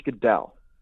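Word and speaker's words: Guidall: It's George Goodell. He won an award for like most audio narrator books. – So that's what he does professionally. He's professionally narrates audiobooks. George Goodell? – George Goodell.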